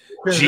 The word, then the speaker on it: Krasinski: Krasinski. Jesus. I said it right. – no, you right. did not. That was not even close. John, John Krasinski drink.